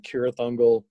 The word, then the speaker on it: Cirith Ungol: and they actually pronounce it with a K, like Cirith Ungol.